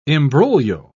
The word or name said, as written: imbroglio